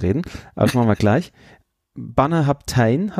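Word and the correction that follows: Bunnahabhain: habe ich. – Bonnehaven. – Bonnehaven, 25 Jahre.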